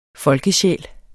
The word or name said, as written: folkesjæl